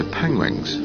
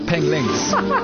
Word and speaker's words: penguins: So why are these woodlands so attractive to penguins? – Penglings.